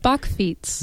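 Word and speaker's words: bakfiets: Because I think You know, you see a lot of bikes that are the Bockfeets around town. And mm-hmm. if I just butchered that, I apologize. I've never actually heard somebody say it. – Bockfeets. – There we go.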